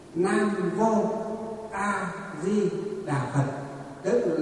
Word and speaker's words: vô: Cái chữ mô á, chữ mô nó nguyên chữ Hán nó đọc là chữ vô.